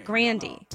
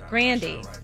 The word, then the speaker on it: Grande: Ariana Grande says that her family pronounced her last name Grandy as if rhyming with candy, but her brother changed it to Ariana Grande when she started getting famous. But her name is pronounced Grandy.